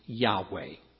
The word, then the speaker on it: Yahweh: From the study of the structure of the Hebrew language, virtually all scholars today agree that YHWH or YHVH with the proper Hebrew vowels inserted should be pronounced Yahweh. That's YAHWEH, Yahweh.